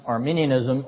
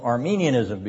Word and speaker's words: Arminianism: You do not pronounce Arminianism Arminianism because you're confusing an ethnic group, which is Eastern Orthodox in confession and belief, with a Protestant group of mostly Methodists and others.